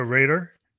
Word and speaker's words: Rader: This is Fam Electric Ghost, and we're live on the air with Victoria Rader.